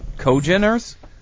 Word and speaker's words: congeners: Congeners? How would you pronounce that? That's the complex it picks up from wood. C-O-N-G-E-N-E-R-S. Cogeners? – Congeners.